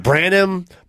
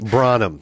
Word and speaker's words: Branum: The Karen Kilgariff, who introduced me on the comedy album I released a couple of years ago, mispronounced my last name. Oh. She said Guy Branum. – Branum.